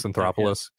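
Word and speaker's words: Anthopoulos: According to the Braves general manager, Anthropoulos, I think is how you pronounce it. – Yeah, Alex Anthropoulos.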